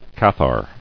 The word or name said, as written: Albigeois